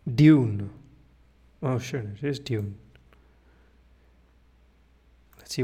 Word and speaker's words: Dune: I'm going to say Dune throughout this book, Dune, because that's how I think it should be pronounced, not Dune, Dune.